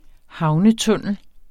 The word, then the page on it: havnetunnel: -ˌtɔnˀəl; -tuˌnεlˀ